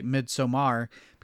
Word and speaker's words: Midsommar: I will say that producer wife and I, we saw Midsommar, or I will say Midsommar, because that's how Ari Aster pronounces it in all the DVD extras.